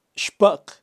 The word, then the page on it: xpaq: Kaqchikel